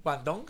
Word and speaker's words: Guangdong: mientras que las autoridades de Guangdong, ¿se pronuncia bien? – Guangdong, sí.